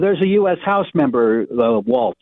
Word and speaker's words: Walz: So, I was really upset that they kept pronouncing our governor's name wrong. Did you see that? – Yeah, Walz instead of Walz. – Yes, what was that about? There's no T in the man's name.